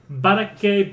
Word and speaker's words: Boracay: Stop doing that with your R's. – The Philippines have a lot of Spanish, So why are you pronouncing the R like it's a D? – Boracay.